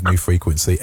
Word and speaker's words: NWFRQNC: Now, there's a great spelling of New Frequency, N-W-F-R-Q-N-C, but it's pronounced New Frequency.